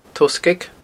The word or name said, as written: toghsgaig